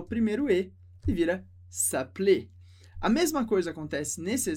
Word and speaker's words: s'appeler: Perceba que a pronúncia do primeiro é mais fechada, s'appeler, e a segunda bem aberto, m'appelle, m'appelle.